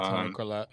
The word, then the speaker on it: Collette: but great actresses and actors like Toni Collette or Collette, I don't know how to say her name. – Yeah, Toni Collette.